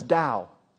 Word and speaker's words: Dow: Like my last name is Dow, D-O-W. The Dow Jones, Dow Chemical.